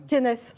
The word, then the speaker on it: Kennes: Ça se prononce comme ça Kenes. Kenes, voilà. Je savais bien que j'allais me planter.